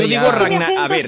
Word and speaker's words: Ragnarok: Que es el Ragnarok, se pronuncia con R fuerte, yo lo he pronunciado Ragnarok toda la vida.